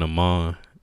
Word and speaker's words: Aman: Like, as far as my middle name being Amon, even the spelling, it's spelled A-M-A-N. It's spelled like A-man.